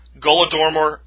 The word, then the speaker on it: Holodomor: Golodomor, which is pronounced with a G, but spelled with an H, like Holodomor, but spelled or pronounced Golodomor, is literally translated from Ukrainian to English to mean murder by starvation.